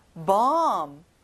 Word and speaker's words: bomb: 폭탄, bomb. 어 소리가 나고요. 끝에 b 발음은 안 나요.